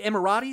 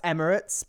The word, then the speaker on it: Emirates: Is it Emirates? Is that it? – No, that's Emirates.